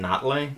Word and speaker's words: Nathalie: We thought it was Nathalie because it's spelled N-A-T-H-A-L-I-E. – Whenever I've heard anyone ever talk about her, it's always just been Natalie.